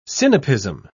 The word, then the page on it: sinapism: British English